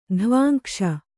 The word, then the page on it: ಧ್ವಾಂಕ್ಷ: dhvāŋkṣa